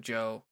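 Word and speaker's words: Jo: Uh, I am on a couple different social media sites under the handle Ghost of Joe, and my name is spelled J O, so it is Ghost of J O.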